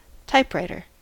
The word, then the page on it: typewriter: American English